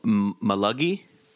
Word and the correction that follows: Maligi: Oh. – Is that how you say it? – Ulrich Maligi? – Maligi. – I believe that's it.